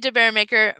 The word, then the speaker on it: DeBaermaeker: It's Debbie DeBaermaeker, maker of things, and my last name is spelled with a M-A-E-K-E-R, so maker is M-A-E-K-E-R of things.